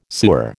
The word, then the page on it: sewer: American English